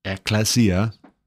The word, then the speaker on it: ecclesia: The three institutions are, Luther says, the church, or ecclesia, that's how you say it, by the way, ecclesia, the household economy, or oikonomia, and politics, or politia.